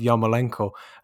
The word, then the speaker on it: Yarmolenko: And I think that, you know, your pronunciation of Yarmolenko there is absolutely spot on. You know, it's what you'd get sort of as if you were listening to the Ukrainian commentary on a local game.